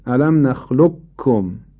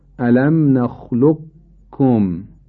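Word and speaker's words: ألم نخلقكم: ادغام ناقص در اینجا به این معناست قاف رو بدون صفت قلقله تلفظ کنیم، این معنای ادغام ناقص قاف در کافه. به این ترتیب الم نخلقکم، الم نخلقکم، من نمیگم الم نخلقکم، کاف نمیگم، عرض میکنم الم نخلق کم.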